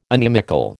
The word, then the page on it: inimical: American English